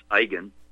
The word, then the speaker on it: Eigen: as Max Eigen, phonetically pronounced I-G-A-N, Max Eigen of Aussie Land, in his presentation, COVID-19 Fraud, Real Issue Genocide.